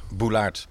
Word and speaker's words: Georges Boulaert: Ik zou zeggen Georges Boulaert. – Boulaert, precies.